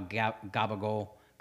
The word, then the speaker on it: capicola: It's how Italians spell things versus how they pronounce things. And so, like, this is like how gabagol. Is spelled Capicola.